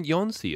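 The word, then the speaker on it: Jónsi: Oh, it's a soft J in Jonsi as well.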